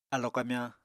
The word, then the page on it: alo kamyañ: apatani